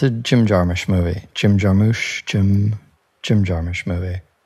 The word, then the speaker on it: Jarmusch: It's a very soft U. It's a U it's a Jarmusch.